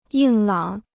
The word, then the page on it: 硬朗: yìng lǎng